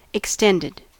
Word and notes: extended: American English

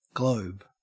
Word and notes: globe: Australian English

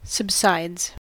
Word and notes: subsides: American English

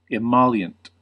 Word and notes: emollient: American English

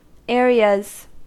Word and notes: areas: American English

